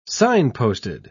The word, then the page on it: signposted: British English